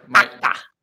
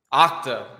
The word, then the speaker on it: Okta: Am I saying it right? Or Okta. – Okta. There you go.